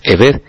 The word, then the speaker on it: Eved: Pero espiritualmente, siervo y esclavo, que se describen con las letras hebreas, y que la pronunciación es Eved, serían lo mismo ante el Eterno, teniendo en cuenta este significado que estamos comentando.